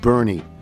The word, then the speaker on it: Boerne: From Mary Faye Randolph, B-O-E-R-N-E is pronounced Bernie. It's in the Texas Hill Country. B-O-E-R-N-E, I never would have thought.